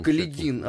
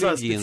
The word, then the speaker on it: Каледин: ты так произносишь обычно Каледин? – Каледин, а, он специ... Каледин, специально да, уточняли, да, так, там чтобы Марине именно...